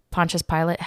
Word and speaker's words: Pontius Pilate: Pontius Pilate? How do you? – That's correct. – It's Pontius? Okay. I've always said Pontius, and people are like, that's not how you say it.